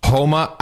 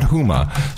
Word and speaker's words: Huma: Actually, we have the official pronunciation. It's, Homa Abedin. You don't really pronounce the D, which, by the way, our Pakistani producer sent us the pronunciation. He said it's Homa, not Huma. So you would presume that if you knew Homa, you would say Homa, right? And not Huma, right? – That's what you'd presume, yes.